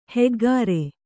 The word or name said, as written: ಹೆಗ್ಗಾರೆ